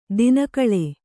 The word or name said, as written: ದಿನಗಳಿ